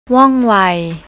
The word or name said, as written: wongwai